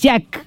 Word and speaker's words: JAC: Lo lee mal de nuevo, marca JAC, no JAC, se lee JAC, se escribe JAC, JC, pero se lee JAC, ¿ya?